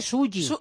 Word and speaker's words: Sully: Clint Eastwood, que tiene ya 86 años y yo creo que no puede estar más guapo, estrena una película titulada Sally, que tiene el que se escribe Sully. – Sully, efectivamente Sully, que